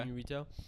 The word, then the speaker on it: Iñárritu: And directed by Alejandro Iñárritu. – Yes. – I don't know if I'm pronouncing that correctly or not. Okay. – Iñárritu.